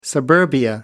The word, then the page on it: suburbia: American English